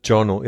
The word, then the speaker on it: Giorno: Und das G spricht man mehr so wie ein J aus, ne, wenn da kein H dahinter ist. – Äh, Giorno, ja.